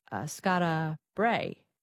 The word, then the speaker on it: Skara Brae: seeing the Ring of Brodgar and also Skara Brae. Is that how you pronounce that? Yes. – Yeah.